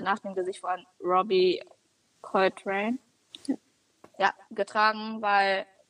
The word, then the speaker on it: Coltrane: Okay, ich habe noch, vielleicht, ich glaube, das wissen die meisten, also es gab zwei Hagrids, einmal Robbie Coltrane oder wie auch immer, ich bin ganz schlecht im Namen aussprechen, ich kann das echt nicht. – Robbie Coltrane, ja, stimmt.